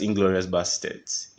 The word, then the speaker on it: Inglourious Basterds: That's Inglourious Basterds. It's not Bastards. Bastards. – But then you pronounce it Inglourious Bastard.